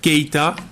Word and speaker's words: Keita: Keita. – Keita. – I can spell it K-E-I-T-A.